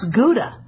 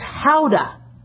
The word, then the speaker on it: Gouda: That's not how you pronounce Gouda. It's Gouda. That's apparently from the Netherlands. It's named after the city in the Netherlands, and they pronounce it with an H.